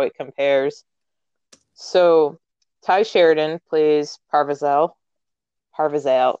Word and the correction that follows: Percival: Yeah, Percival.